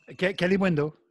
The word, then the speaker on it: Kalimuendo: Kalimuendo, yeah.